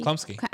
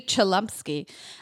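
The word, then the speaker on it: Chlumsky: Klumsky? – Klumsky. – Anna Klumsky. No, it looks like it should be Chalumsky.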